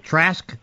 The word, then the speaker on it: Trask: Trask, yeah.